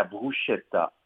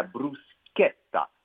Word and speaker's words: bruschetta: beaucoup de Québécois vont dire la bruschetta. Erreur. C'est la bruschetta. – La bruschetta. – La bruschetta. Pourquoi? Parce qu'en italien, le CH se prononce K.